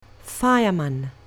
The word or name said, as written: fireman